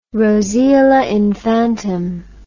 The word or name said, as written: roseola infantum